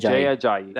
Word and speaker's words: Jay Ajayi: Jay Ajayi.